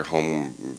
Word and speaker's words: Hom: You know, mostly it's just like the average, almost actually your home furniture type furniture that you see everywhere. – It's pronounced Hom. – Hom furniture. – There's no E.